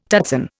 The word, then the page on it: tutsan: Indian